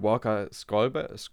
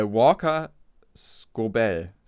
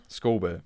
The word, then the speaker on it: Walker Scobell: Was mir wirklich gut gefallen hat, war der, das Schauspiel von äh, Walker Skolber. oh Gott, ich habe hab ihn extra aufgeschlagen, weil ich den Namen sicher wieder falsch aussprechen werde. Äh, ich suche ihn mal irgendwas kurz. Äh, Walker Scobell. Ja, Scobell. Scobell, wahrscheinlich. Walker Scobell, ähm, der hier tatsächlich sein Debüt hatte.